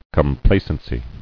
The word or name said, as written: Complacency